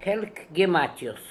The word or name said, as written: Helkgematios